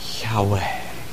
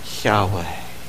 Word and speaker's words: Yahweh: And even if they were to pronounce it, the word Yahweh is a breath word. Say that word, Yahweh, Yahweh. You can't say that word and close your lips.